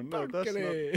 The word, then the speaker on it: Perkele: No, Perkele. That's not. That's not how you say it.